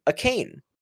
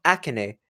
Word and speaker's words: Akane: Or if you say it like him, a cane. Because A-K-A-N-E. – A cane. – Does he actually say it like that? – It's written like the Japanese way, so it's like Akane.